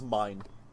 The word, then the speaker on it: Meine: I do not know the pronunciation of his last... Klaus Meine? – I believe it's just Meine.